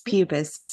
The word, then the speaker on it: Puppis: The neighboring constellations are Columba, Lepus, Monoceros, and Pupis or Puppis. What say you? Okay, you say nothing. The constellation named Canis Major. – Pupis. Sorry, I was reading something. It's Pupis.